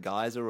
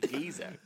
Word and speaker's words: Giza: Sorry, is it pronounced any other way? Can it be? Can like geyser or Giboo? – No, it's what? – It's just Giza. – I think it's Giza. Yeah. – Giboo. No, it's pronounced Giza.